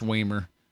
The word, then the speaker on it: Wamer: That's W-A-M-E-R. That's the other easy way to find it. And all the episodes. – Is it Wamer or Wamer? Wamer would be a lot cooler. – It would. I'm pretty sure it's Wamer. – Yeah, that sounds about right. – Which rhymes with lamer.